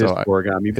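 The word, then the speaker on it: Scorigami: Yeah, and by the way, it's Scoragami. I always mispronounce that. – It is Scoragami.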